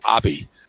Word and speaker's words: Abe: You know, like the name A-B-E, which is a last name in Japan, a very common last name, A-B-E, is not pronounced Abe. It's Abi.